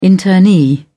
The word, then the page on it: internee: British English